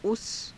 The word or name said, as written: usa